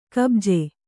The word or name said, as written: ಕುಬುಜ